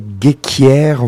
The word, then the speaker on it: Ghekiere: Des nouveautés, donc l'album de l'année dernière de Abel Guéquière, on va le prononcer comme ça s'écrit G-H-E-K-I-E-R-E.